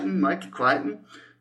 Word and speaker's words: Michael Crichton: Michael Crichton.